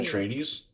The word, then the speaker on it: Atreides: Atrides? Atrides? How do you say that guy's name? – Atreides. – Atrides? – Atreides. – Atreides? Like Paul? – Yeah.